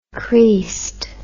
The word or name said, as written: creased